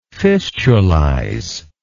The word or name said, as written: fistulize